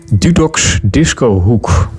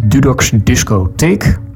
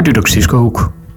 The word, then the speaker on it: Dudok's Disco Hoek: Dudok'S Disco Hoek. Zo heet het. Dus niet Dudok'S Disco Take. Zoals diverse malen wordt aangekondigd gedurende dit programma. Maar Dudok'S Disco Hoek.